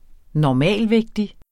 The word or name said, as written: normalvægtig